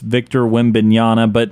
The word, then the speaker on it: Victor Wembanyama: Yeah, no, it's a difficult name to pronounce. It's Victor Wembanyama, but yeah, he's actually 7'4", 7'5".